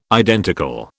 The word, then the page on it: identical: British English